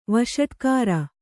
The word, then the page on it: ವಷಟ್ಕಾರ: vaṣaṭkāra